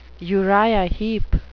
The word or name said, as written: Uriah Heep